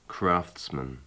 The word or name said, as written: craftsman